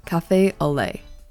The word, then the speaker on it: café au lait: An example of this is café au lait.